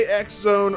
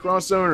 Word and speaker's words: X Zone: To say X Zone or Cross Zone? Are you, you obeying the company's wishes and calling it Cross Zone, or do you pronounce it X? – You know, I probably did say cross, but then for today's episode, since it's X related, I went with the X.